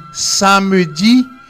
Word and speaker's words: samedi: S-A-M-E-D-I. Samedi. Samedi. Okay. Ça s'écrit samedi, pero se prononcia samedi.